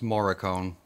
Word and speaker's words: Morricone: It's Morricone. You always say it wrong.